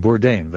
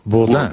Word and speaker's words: Bourdain: And you insist on the American pronunciation, Bourdain, though it's a French name. – Yeah. – It should be pronounced Bourdain.